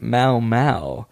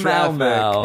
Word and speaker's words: Momo: She wanted me to shout out her YouTube channel, but she says it's pronounced Mau Mau, and a space between Jess and the M-O-M-O show. Jess space M-O-M-O show, pronounced so Mau Mau.